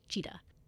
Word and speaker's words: Chitat: And his middle name is C-H-I-T-A-T. It's pronounced Cheetah.